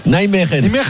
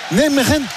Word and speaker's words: Nijmegen: Ah, comment on dit ? – Nijmegen. – Nijmegen. Nijmegen. Voilà, il vient de Nijmegen. C'est facile de le dire.